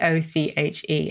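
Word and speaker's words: synecdoche: Brief is another example of what is called sinec docu. Sorry, I'm laughing because I mispronounced this earlier, so you're hearing the edited version. So it's sinecdoche, and it's a figure of speech in which one part is made to represent the whole.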